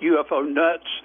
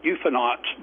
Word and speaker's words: UFOnauts: I'll have to be careful with that because people have pronounced it UFO nuts. So in the trade, we call it UFONauts.